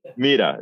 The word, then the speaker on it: Mira: If you speak Spanish, it's like Mira, yeah. Mira. – Mira.